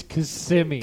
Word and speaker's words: Kissimmee: It's Kissimmee.